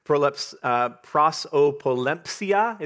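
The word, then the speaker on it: prosopolempsia (προσωπολημψία): It's prosopolempsia is how you say it, kind of a fun word in Greek.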